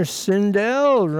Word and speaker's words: Sindel: Sindel rhymes with hell.